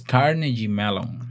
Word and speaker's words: Carnegie Mellon: Es Carnegie Mellon. ¿Cómo fue? Carnegie Mellon. – Carnegie Mellon.